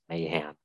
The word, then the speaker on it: Mahan: A is like the most versatile vowel in the entire English language. So having two A's in my name makes it hard. The way I pronounce it is Mahan, but I've gotten every different variety of that last name pronunciation, as you can imagine.